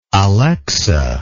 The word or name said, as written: alexa